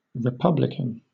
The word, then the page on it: republican: British English